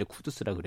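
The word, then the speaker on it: 쿠두스라 (قدس): 페루 페르시아어 이라는 페르시아를 쓰니까 페르시아어 발음이 이제 쿠두스라 그래요.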